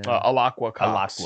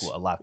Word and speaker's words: Alaqua: How do you say her name? Kotz? – Which character? – Who played Echo? Elena? – Alacqua Kotz. – Alacqua. Yeah. I really liked Alacqua Kotz.